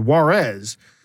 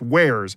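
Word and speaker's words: warez: There used to be, I remember the first time I heard about it, someone wrote down Wares, W-A-R-Z, and I said, Juarez. They said, no, it's pronounced Wares.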